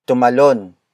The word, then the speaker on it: tumalon: Tumalon, siya, nang, mas, mataas. The first word is tumalon. Tumalon. Tumalon. Tumalon is spelled as T-U-M-A-L-O-N.